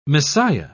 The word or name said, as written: messiah